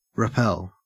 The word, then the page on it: repel: Australian English